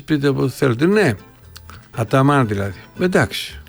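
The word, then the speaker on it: Ataman: Άταμαν λέει, σωστά το λέτε, είχα ακούσει τον Τερίμ να τον λέει Αταμάν, δεν ήξερα τι έλεγε. Ερντογάν λέμε, Ερντογάν δεν λέμε, ναι. Σουλεϊμάν, Μπαϊκάλ, Τσιλέρ, Τάντσου, Τσιλέρ. Θυμάστε Τάντσου. Όλες οι τουρκικές λέξεις τονίζονται στη λίγουσα.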